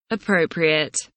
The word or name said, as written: appropriate